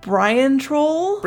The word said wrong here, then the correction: Brintroll: Bryn Troll. – The Bone Arbiter? Brintroll. So